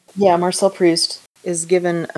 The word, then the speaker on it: Proust: And, of course, that's straight from, I don't know how you say it, Proust? – Proust, yeah.